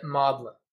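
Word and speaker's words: Magdalen: Yeah, the British, I think there's a, it's spelled Magdalene College of Oxford, but they say it Maudlin.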